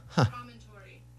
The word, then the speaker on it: promontory: How would you say it? Promontory? – Promontory.